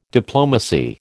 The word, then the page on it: diplomacy: American English